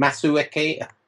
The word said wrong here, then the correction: Masuaku: Masuaku. – Masuaku.